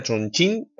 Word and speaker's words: Chongqing: Sí, eh, se pronuncia Chongqing, que ya sé que puede dar eh, bueno, pie a muchas bromas.